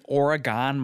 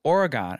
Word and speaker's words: Oregon: Do you know I was pronouncing it Oregon my whole life? – Really? – And got ridiculed when I moved to L.A. – Yeah. – Then I called my family and was like, how do you pronounce that state up north? And they were like, Oregon.